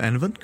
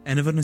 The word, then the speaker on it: Enevun: Hello there, I'm Enovan, currently wearing a Christmas sweater to get into the season.